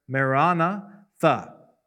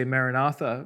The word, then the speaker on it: maranatha: And then he says, our Lord come, which in the Greek is this, these two words, maranatha. Usually in English we say maranatha, but in the Greek the accent is on the second syllable, pretty clear. Maranatha, okay?